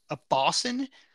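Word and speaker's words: abaucin: It's called a Bausin, A-B-A-U-C-I-N. I'm going to assume that's how it's pronounced.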